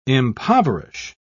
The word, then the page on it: impoverish: British English